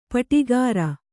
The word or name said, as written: ಪಾಡುಗಾರ